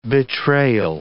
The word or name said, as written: betrayal